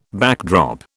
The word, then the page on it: backdrop: British English